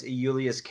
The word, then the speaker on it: Iulius: So Gaius Julius Caesar is Gaius Iulius Caesar.